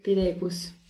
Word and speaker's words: Pideequus: ¿Cómo nacieron eh, estos proyectos de Doma y mucho más y más recientemente de Pidecus? No sé si lo digo bien. – Sí, Pidecus.